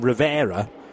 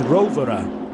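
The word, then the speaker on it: Rovera: Many Italians were correcting John, and a few others as well, Martin Haven, I think, because we've always called him Rivera, but the correct Italian pronunciation is Rovere.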